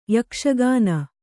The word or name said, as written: ಯಕ್ಷಗಾನ